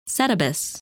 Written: Setebos

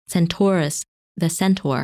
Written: Centaurus

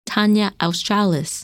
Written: Tania Australis

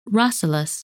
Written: Rasalas

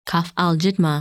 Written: Kaffalijidhma

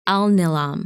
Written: Alnilam

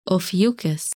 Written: Ophiuchus

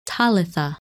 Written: Talitha

